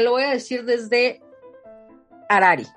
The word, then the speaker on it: Harari: Dinos cómo se pronuncia. – Harari, porque H es muda.